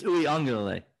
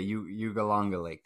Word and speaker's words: Uiagalelei: You have DJ Ugalde. That's how you pronounce it.